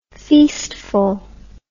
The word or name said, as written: feastful